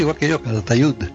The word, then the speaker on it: Calatayud: Pero que muchos madrileños lo pronunciarían Calatayud. – Con Z, pues lo mismo que también hay muchos que te dicen Madrid.